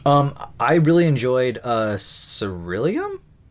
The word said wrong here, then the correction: Cerulean: Is that how you pronounce that? – Cerulean, I think it is. – Yeah, Cerulean. Yeah, Cerulean.